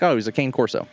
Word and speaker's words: Cane Corso: Well, it's spelled C-A-N-E, and they call it Carne. Carne Corso.